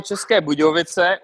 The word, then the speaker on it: České Budějovice: Nämlich bei, jetzt werde ich auch gucken, ob ich das richtig ausspreche, Dinamo České Budějovice oder so. Dinamo České Budějovice und das ist ein Stadt vom, das ist Bierstadt.